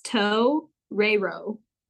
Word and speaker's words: torero: T-O-R-E-R-O. I just like how it's spelled. It's Toe Rero. – Do you know what it means? T-O-E-R? – Bullfighter.